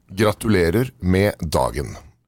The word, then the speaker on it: Gratulerer med dagen: Gratulerer med dagen.